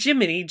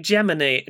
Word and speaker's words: Gemini: not Jiminy, Gemini.